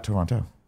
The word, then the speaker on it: Toronto: I'd say it like the natives of Toronto. They say, Toronto.